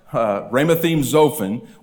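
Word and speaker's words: Ramathaim-zophim: Ramathaim Zophim,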